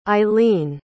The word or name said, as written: eileene